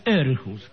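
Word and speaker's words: Århus: Jan Hein dice, saludo desde Dinamarca, desde un pueblo cuyo nombre se escribe Artus... No, Arjus, con un circulito, un redondelito arriba de la letra A. Y se pronuncia Orjus. – Sí, se pronuncia Erjus, que es distinto, ah, pero bueno.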